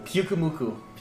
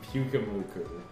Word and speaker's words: Pyukumuku: How do you say that Pokemon's name? – Pyukumuku. – Pyukumuku.